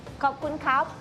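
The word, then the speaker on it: Kopun kap (ขอบคุณครับ): Kopun kap.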